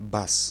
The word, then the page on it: bass: /beɪs/; /bæs/